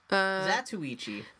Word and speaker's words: Zatoichi: Criterion's, I'm going to say this wrong, Zatoichi? – That's pretty close. – Zatoichi.